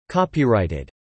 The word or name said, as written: copyrighted